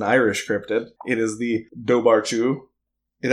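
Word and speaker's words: Dobharchú: And I guess the pronunciation, it's spelled Dobarchu, but the pronunciation would be Duwarchu, because Irish is...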